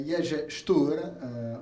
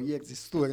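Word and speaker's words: Jerzy Stuhr: E questo era Jerzy Stur, o Jerzy Stur, insomma, a seconda della pronuncia, se la facciamo alla polacca o all'italiana.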